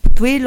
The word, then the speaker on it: Ptuj: Bueno, Ptuj lo pronunciaban los eslovenos. Realmente se escribe Ptuj, así que nosotros quizás lo diríamos de otra manera.